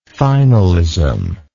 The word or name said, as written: finalism